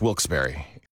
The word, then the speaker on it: Wilkes-Barre: The only way to pronounce Wilkes-Barre.